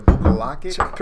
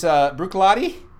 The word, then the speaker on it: Bruichladdich: Yeah, it's a Bruichladdich. – Sure, you're right. So this it sounds just like it's spelled. Not at all. It's opposite. It's opposite day, spelled like it sounds. It's Bruichladdich. It's spelled Bruichladdich.